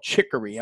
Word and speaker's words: Chicory: Chicory. Am I pronouncing it correctly? – Yes. – Chicory? – Yep, absolutely.